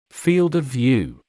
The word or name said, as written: field of view